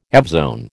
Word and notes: evzone: American English

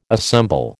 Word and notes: assemble: American English

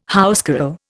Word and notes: housecarl: Indian

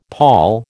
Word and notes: pawl: American English

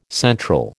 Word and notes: central: American English

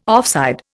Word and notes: offside: Indian

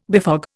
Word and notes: befog: Indian

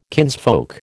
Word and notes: kinsfolk: American English

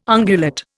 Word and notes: ungulate: Indian

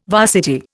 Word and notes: varsity: Indian

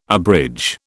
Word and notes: abridge: British English

